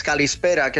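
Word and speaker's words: calispera (καλησπέρα): Mira, cuando te digo buenas tardes, calispera, qué sonido más bonito, y es súper fácil de decir calispera para caló.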